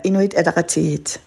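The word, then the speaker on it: Inuit Ataqatigiit: Hvordan er det, du øh, udtaler hele partiets navn? – det er Inuit Adaratit.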